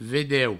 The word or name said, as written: vedeu